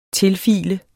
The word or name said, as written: tilfile